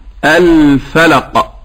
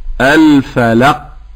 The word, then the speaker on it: الفلق: نقول الفلق وليست الفلق. – ما الفارق فضيلة الشيخ؟ – هذا النطق لو قلت الفلق هكذا شددت القاف.